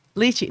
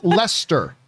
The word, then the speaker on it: Leicester: Leachie. Nope. Never mind. – Some people say it's pronounced Lester. – Lester. – I thought it was Leicester. Okay. Lester. All right.